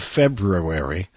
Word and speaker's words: February: Good afternoon, and welcome to the Large Morning Show in the afternoon on this, the 24th day of February. That's the correct way to pronounce it.